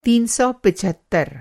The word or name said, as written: तीन सौ पचहत्तर